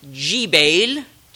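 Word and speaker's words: Jbeil: Tyr au sud, Gébal, en arabe, on prononce G, on prononce Jibail, au nord du Liban.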